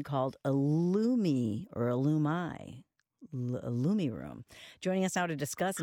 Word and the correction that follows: IllumiRoom: Hey, I think it's Illumiroom. – Illumiroom, okay. – Yeah. You're pretty close either way, really. – Yeah, I guess there's kind of just, it's more about the cadence of how you say it.